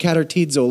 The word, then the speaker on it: katartizo: So you just have to imagine what the word katartizo looks like in your mind. Spelled with a K-A-T-A-R-T-I-Z-O.